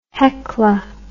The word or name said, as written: Hekla